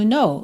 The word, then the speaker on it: know: Let you, muito comum essa junção, né, de uma palavra terminando em T com you, fica to, let you know. No, o K é mudo, right?